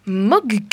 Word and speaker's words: Mogk: Donc alors aujourd'hui, je vais vous parler du livre Culture Zombie de, attention, c'est impossible à prononcer, Matt Mogg. M-O-G-K.